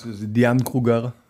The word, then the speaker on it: Diane Kruger: Diane Kruger.